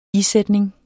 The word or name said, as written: stivnet